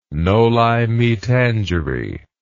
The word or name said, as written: noli me tangere